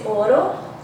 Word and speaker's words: Ouro: And I don't know, I think that feels kind of special because, you know, I would say Ouro. Yeah, so. – It sounds so much nicer when you say it. I know. I say Ouro.